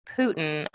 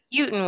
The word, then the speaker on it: Putin: I believe it is pronounced Putin. I think Putin would be as in Rasputin, who passed away.